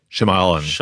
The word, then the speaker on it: Shyamalan: Yeah, Shyamalan.